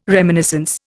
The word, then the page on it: reminiscence: Indian